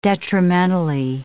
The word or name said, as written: detrimentally